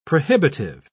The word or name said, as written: prohibitive